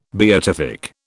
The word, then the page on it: beatific: British English